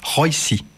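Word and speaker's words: Róisín: Alors, elle est irlandaise, il faut prononcer Roisy.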